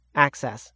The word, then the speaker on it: AHCCCS: Arizona's $23 billion Medicaid program is called the Arizona Healthcare Cost Containment System, or AHCCCS, pronounced ACCESS.